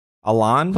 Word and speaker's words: Alain: Alain? – Close enough, yeah.